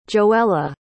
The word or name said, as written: joella